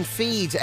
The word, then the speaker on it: Fiid: It came in from the lads in Feed, F-I-I-D it's pronounced, right? – Okay.